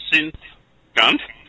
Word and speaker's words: SinthGunt: Synth Gunt. It's SINTHGUNT. Okay, no S in there anyway, except for that at the start, obviously.